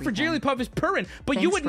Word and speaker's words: Jigglypuff: The word Jiggly has two syllables. The word Puff has one. When you combine them into Jigglypuff, it becomes a three-syllabled word.